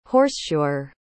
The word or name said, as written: horseshoer